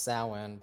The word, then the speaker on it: Samhain: Well, it's pronounced Samhain, but you know, it's spelled Samhain.